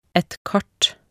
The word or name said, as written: et kart